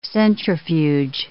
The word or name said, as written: centrifuge